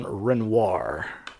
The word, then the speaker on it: Renoir: uh, some notable deaths uh, John Wayne, of course, died in 1979, uh, Arthur Fiedler, uh, Charles Mingus, uh, Jean Renault, and not Renault, not Jean Renault, not, not that guy, Jean Renoir. Sorry. Again, mispronounced.